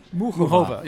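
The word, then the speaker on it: Muchová: And as for Carolina Muchova, how do you say her name? – Muchova. – Yeah, because if you go, you listen to her, the way she says it, it's always a little different.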